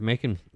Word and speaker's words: Macon: What, it's Macon? – Like, it's Macon, Georgia, 100%. Is it Macon? 100%. 100%. – Macon? – 100%. – Bacon? – Like bacon? It's spelled just like bacon, but with an M.